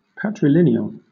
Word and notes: patrilineal: British English

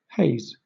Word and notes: haze: British English

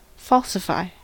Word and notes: falsify: American English

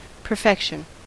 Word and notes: perfection: American English

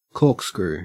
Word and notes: corkscrew: Australian English

